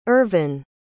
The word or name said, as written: irvyn